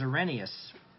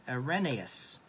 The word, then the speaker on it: Irenaeus: The first one is Irenaeus. Irenaeus. It's spelled I-R-E-N-A-E-U-S, for those who are I-R-E-N-A-E-U-S.